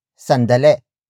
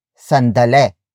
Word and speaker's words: sandali: Tagalog speakers tend to relax the E sound at the end, making it sound like E instead. So what should have been pronounced as sandali, sandali, sandali becomes sandale.